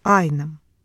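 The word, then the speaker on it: Einem: В 1846 году в Москве появляется молодой немец, 20-летний, которого по паспорту зовут Фердинанд Теодор фон Эйнем. По-немецки фамилия произносится Айнем.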